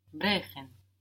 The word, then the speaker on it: brechen: Brechen. – Brechen. So here we have an E before the CH. It's giving us a soft CH.